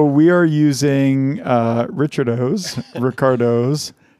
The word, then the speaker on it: Richardo's: And it is, I don't know if this is pronounced Ricardo's or Richardo's. I'm assuming it's Ricardo's. – I think so. – But Ricardo doesn't usually have an H in it, does it? – It's Richard O's.